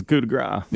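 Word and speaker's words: coup de grâce: I mean, tell you what, the 12 men on the field, it's a coup de grace. – There you go. That's it. – That's how you say it.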